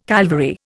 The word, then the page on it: Calvary: Indian